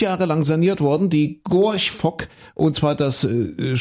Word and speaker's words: Gorch: Denn äh, was ich kann George, George Fock nicht aussprechen. Gorch. George, Gorch. Gorch. Gorch. Das ist ganz einfach.